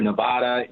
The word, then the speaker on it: Nevada: Sometimes you hear Nevada, you know that this is new to them. It's Nevada, and that's important for everybody who comes to organize here.